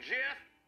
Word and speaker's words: Geoff: People named Jeff, who spell it G-E-O-F-F. Yeah, I know it's pronounced Jeff, but when I see it on paper, my brain reads git.